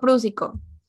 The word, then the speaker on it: prúsico: La cosa es que Lizzie fue a su farmacia y dijo, mi familia está enferma, necesito comprar ácido, que es ácido prósico. cómo se dice eso. Ácido prúsico.